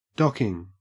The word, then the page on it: docking: Australian English